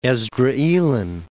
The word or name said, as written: Esdraelon, Plain of